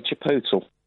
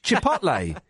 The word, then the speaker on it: chipotle: I say chipotle.